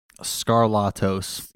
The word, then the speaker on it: Skarlatos: It's look, pronounce it like Trebek. Trebek. – What is the exact pronunciation of the last name? – Skarlatos.